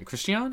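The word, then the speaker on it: Christiane: however the fuck you say her name. Christian? – That's how you say it, yeah.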